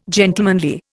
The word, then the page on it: gentlemanly: Indian